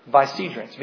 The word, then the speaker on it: vicegerents: And I want you all to learn a new word with me here today. Vicegerents. V-I-C-E-G-E-R-E-N-T-S. Vicegerents.